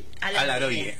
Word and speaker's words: Alaroyé: Lo quilmenizamos, claro, porque en realidad, ¿cómo se pronuncia? – Alaroye. Tiene dos acentos. – ¿Qué despelote? Palabra quicho aquí. – Doble acento tiene.